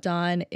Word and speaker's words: DAVVN: So make sure you check out Dawn if you haven't yet. It's spelled D-A-V-V-N, two V's, not a W.